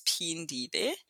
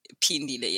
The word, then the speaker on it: Phindile: Yes, it's so my the H in my name is silent. It's Phindile. Yeah, Phindile, yeah. So the H is silent.